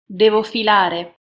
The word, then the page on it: devo filare: Italian